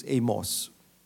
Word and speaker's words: Amos: The Americans pronounce this as Amos.